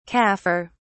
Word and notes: kafir: British English

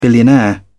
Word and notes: billionaire: British English

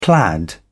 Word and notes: plaid: British English

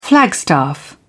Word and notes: flagstaff: British English

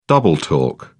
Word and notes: double talk: British English

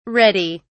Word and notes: reddy: British English

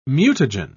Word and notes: mutagen: British English

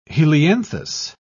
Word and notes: helianthus: British English

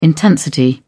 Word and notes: intensity: British English

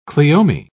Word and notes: cleome: British English

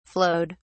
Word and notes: flowed: British English

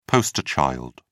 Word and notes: poster child: British English

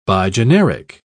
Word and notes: bigeneric: British English

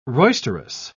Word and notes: roisterous: British English

